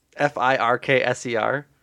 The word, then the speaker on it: Firkser: How would you pronounce it? Through your through your lens. Uh, no, I think you're right. It's just a funny name, Tony Firkser.